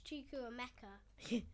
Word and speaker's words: Chukwuemeka: It's Chukwuemeka.